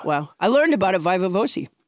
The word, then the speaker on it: viva voce: I did look up the pronunciation. Okay. Yeah, viva voce.